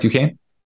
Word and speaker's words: Duquesne: That's how you say it, right, Duquesne? I think so. – Sure. Yeah, that sounds good. Yeah, I think that's right. – I think it's definitely not spelled like that.